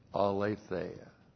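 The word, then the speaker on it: aletheia (ἀλήθεια): Three, the direct object of Lego one of my favorite words, aletheia. A-L, long E-T-H-E-I-A. Aletheia.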